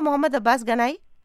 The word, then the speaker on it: محمد عباس گنائی: عباس، صحیح نام لے رہی ہوں میں آپ کا، محمد عباس گنائی؟ – جی، شکریہ، اچھی تلفظ۔